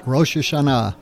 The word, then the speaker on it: Rosh Hashanah: And the first thing, I want to ask you about is this idea of Rosh Hashanah, or Rosh Hashanah. How do I say that? – Yeah, right. Rosh Hashanah, the head of the year.